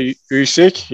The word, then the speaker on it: Usyk: Moving forward, I don't know if everyone would call it an upset or not, but Anthony Joshua dropped his titles to Usyk last weekend. I think that's the right pronunciation, yeah. Fight fans. – Yeah, it is. Alexander Usyk, yep.